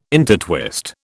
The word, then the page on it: intertwist: British English